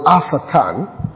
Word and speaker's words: Hassatan: The Hebrew is the word Asatan, can be pronounced and spelled from H-A-S-S-A-T-A-N, or just Satan.